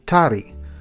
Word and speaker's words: tari: Toddy is from the Hindi word, tari, spelled T-A-R-I, but pronounced with the R sound approaching an English D.